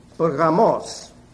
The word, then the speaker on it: Pergamos: The church of Pergamos. You pronounce it Pergamos.